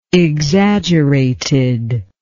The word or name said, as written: exaggerated